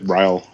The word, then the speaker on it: Ryall: But anyway, so Chris Ryall, how do you figure we pronounce that? Ryall, I think, right? – I just said Ryall, yeah.